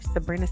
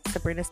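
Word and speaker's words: Shabrina: You can find me on um, Facebook under Sabrina Smith, Sabrina spelled with an H, S H A B R I N A.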